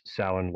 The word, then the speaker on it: Samhain: I've heard it pronounced both ways. I've heard Samhain. I've also heard Samhain. I will tell you that if you listen to the first song in the Shim or Samhain, he flat out says Samhain like several times. So if you are confused how to pronounce it, that is the correct, at least that's how Glenn pronounces it.